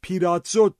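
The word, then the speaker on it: پیراتزو (πειράζω): یادمون باشه کلمه آزمایش در زبان یونانی پیراسموس می باشه که در حالت فعل پیراتزو تلفظ میشه و به معنی آزمودن، یعنی آزمون ایمان زنده.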